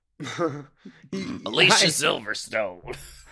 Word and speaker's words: Alicia Silverstone: I'm glad you're saying her name right, A. – Mm-hmm. – And B, kind of. – It ain't Alicia. Alicia Silverstone.